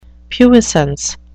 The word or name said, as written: puissance